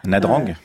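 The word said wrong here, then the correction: Nha Trang: je crois qu'on dit Nyatchan.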